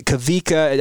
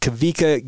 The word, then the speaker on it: Tavita: And Garth is related to Kavika. I know it's spelled Tavita, but it's pronounced Kavika Gagne, who plays on the BYU football program.